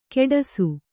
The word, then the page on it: ಕೆಡಸು: keḍasu